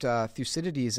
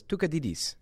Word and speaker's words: Thucydides: And he was Israeli, and he pronounced Thucydides as Tukadidis.